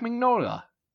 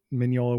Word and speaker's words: Mignola: and Mike Mignola. I just mispronounced his name. – Mignola.